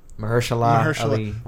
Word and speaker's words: Mahershala Ali: It's going to be Maharsa Ali. – Is it Maharsa Ali? That's how you say it? – Mahershala Ali.